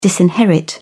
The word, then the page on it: disinherit: British English